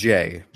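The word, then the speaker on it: Jae: Ozymandias, written by Len Wein, art by Jay Lee, or Jai Lee. I haven't yet figured out how he pronounces that name. I believe it's and, Jay. Jay, okay.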